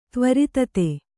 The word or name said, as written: ತ್ವರಿತತೆ